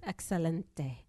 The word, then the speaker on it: excelente: Excelente.